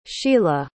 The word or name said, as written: shelagh